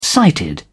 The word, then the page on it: sighted: British English